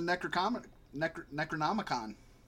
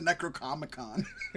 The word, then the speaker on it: Necronomicon: It could wake up the Necronomicon. I said it right the second time. – Yes. – I almost said Necrocomicon, which would be awesome.